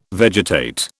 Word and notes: vegetate: British English

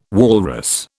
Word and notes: walrus: British English

